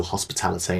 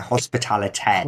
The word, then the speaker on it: Hospitalité: How would they spell that? So it's French, isn't it? Hospitalité. – Hospitalité. So that's HOSPILIT, and then E with a little of the accent on top. Hospitality Notre Dame.